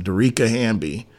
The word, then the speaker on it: Dearica Hamby: Vegas Aces are going to lose their first round 2025 lottery pick over the investigation of Derice Hamby, I believe is how you say it. I hope not probably butchered the name. – Um, Derica? – Hamby. Okay, I'm sorry. It was Dearica Hamby. Dearica Hamby investigation.